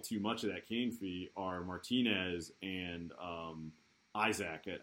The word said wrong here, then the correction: Isak: Isak, yeah.